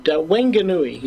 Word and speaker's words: Whanganui: Whanganui, New Zealand. How did I do on that pronunciation? – Pretty good. Whanganui is the, I think, the proper pronunciation.